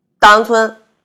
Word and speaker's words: 稻香村: 张罗，张罗，还有好多呢，是就是中间那个字儿啊，就不发音的。稻香村，稻香村。